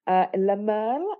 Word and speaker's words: Le Merle: Le Merle, is that how you say it? – Yes, that means blackbird, the blackbird.